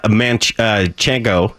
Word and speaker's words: manchego: And you've got smoked aged sharp cheddar, smoked goat cheese, smoked aged Gouda, smoked manchego.